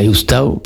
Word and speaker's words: ostau: Voilà, Oustau, pour ceux qui ne parlent pas encore occitan, ça s'écrit O-S-T-A-U.